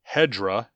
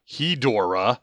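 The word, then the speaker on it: Hedorah: Hedra, pronounced Hedra, not Hedora, the film has honestly grown on me over the years, oozing into my top 10 overall.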